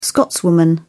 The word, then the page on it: scotswoman: British English